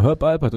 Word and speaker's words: Alpert: Albert, habe ich das richtig ausgesprochen? Du bist genau, Herb Alpert, und der hat dafür einen Grammy sogar gekriegt.